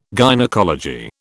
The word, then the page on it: gynaecology: British English